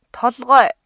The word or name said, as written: толгой